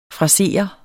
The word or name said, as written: frasere